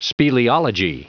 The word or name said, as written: speleology